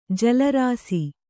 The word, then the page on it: ಜಲರಾಸಿ: jala rāsi; Kannada